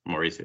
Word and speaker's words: Mauricio: Was it right or was it In Spanish it's Mauricio, but it's Mauricio. It's okay.